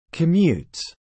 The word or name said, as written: commutes